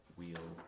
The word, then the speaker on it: weal: So shall thy seed increase and grow, thou shalt overcome in wealth and weal. Well, it's got to rhyme with flow. No, that's later. No, it's wheel.